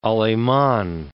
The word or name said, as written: Aleman